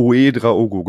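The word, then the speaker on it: Ouédraogo: Ich finde da besonders spannend die Personalie äh, Uedraogo, habe ich das richtig ausgesprochen? Ähm, Uedraogo. Uedraogo, genau.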